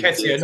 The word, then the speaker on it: Nketiah: You know, Nikita, I think the scariest moment for me in the first half was when Nikita turned Anderson and he was through on goal, but Anderson didn't. Nketiah, not Nikita. Nikita's Elton John's song.